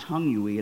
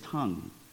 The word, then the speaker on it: tongue: And, and, and let me just give you a little grammatical rule that'll help you, because whenever you see an English word that ends in ue, the UE is silent. So it's not vague UE, it's vague. And it's not tongue UE, it's tongue.